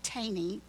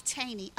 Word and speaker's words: Thoene: In their book, Why a Manger, Bodie and Brock Taney, now for those of you who are taking notes, it's spelled T-H-O-E-N-E. Bodie and Brock Taney elaborate on this point, because they say, why would the Messiah choose to arrive in such an out-of-the-way place as Bethlehem?